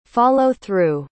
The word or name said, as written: follow-through